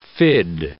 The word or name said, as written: fid